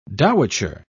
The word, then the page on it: dowitcher: British English